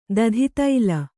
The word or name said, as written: ದಧಿತೈಲ